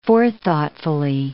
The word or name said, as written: forethoughtfully